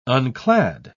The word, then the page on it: unclad: British English